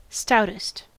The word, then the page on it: stoutest: American English